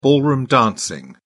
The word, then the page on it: ballroom dancing: British English